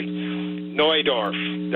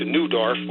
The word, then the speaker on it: Neudorf: Like, for example, I think you mentioned the place northeast of Regina, Neudorf. That would be the proper pronunciation, but somehow it's wandered into Neudorf. Well, it's not spelled N-E-W, it's spelled N-E-U.